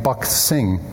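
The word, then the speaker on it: Bakht Singh: There's this amazing story that you can read up as much as you want if you look up the name Buck Singh on the internet.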